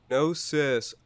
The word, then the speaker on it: Gnosis: Gnosis. Oh, I've been pronouncing it Genosis, but... – Well, I'm glad we did this podcast before it comes out so we can get that straightened out. – How was I supposed to know that the G was silent, you know?